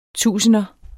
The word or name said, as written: tusinder